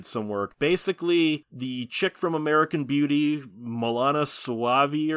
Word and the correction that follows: Mena Suvari: or something like that, she hits Mena Suvari. – Mena Suvari, I can never pronounce her name.